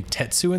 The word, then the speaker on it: Tatsu: I think they pronounce his name Tetsu in this movie, but he's definitely credited as Tatsu.